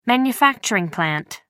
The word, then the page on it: manufacturing plant: American English